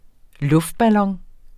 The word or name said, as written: luftballon